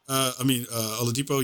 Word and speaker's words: Oladipo: Oladipo, yes.